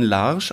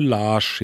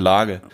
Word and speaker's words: Lage: Aber ich glaube, der Dave King spielt auch bei, wird es tatsächlich Julian Larsch ausgesprochen? – Ich glaube Larsch, ja. – Ja, interessant. Ich hätte natürlich immer gesagt Lage.